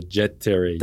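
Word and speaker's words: Jet Tere: I keep pronouncing it weird, but the Babolat Terry? – The Jet Terry.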